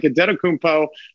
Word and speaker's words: Antetokounmpo: And I'm not saying that he's wrong, but he doesn't say Antetokounmpo. He says like Adetokounmpo, which uh, is the way that I've seen it spelled out phonetically.